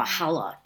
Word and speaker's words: challah: Homemade challah, or challah, as some people pronounce it.